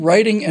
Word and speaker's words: writing: writing, and arithmetic. Get it? Reading begins with an R. Writing, the W is silent, and arithmetic is just a kind of, I'm studying arithmetic.